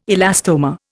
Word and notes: elastomer: Indian

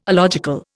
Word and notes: alogical: Indian

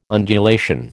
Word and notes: undulation: American English

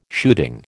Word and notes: shooting: American English

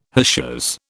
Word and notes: hachures: British English

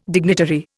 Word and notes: dignitary: Indian

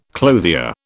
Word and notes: clothier: British English

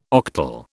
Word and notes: octal: British English

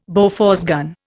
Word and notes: Bofors gun: Indian

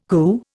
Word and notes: CU: Indian

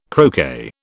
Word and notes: croquet: British English

